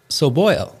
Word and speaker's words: Soboil: Today, my guest is Mark Soboil.